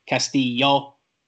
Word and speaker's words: Castillo: Castillo. Okay, it's not two L's. It's a Y.